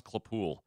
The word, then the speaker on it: Claypool: It's actually pronounced Claypool. – Claypool? – Yeah, yeah, that's it.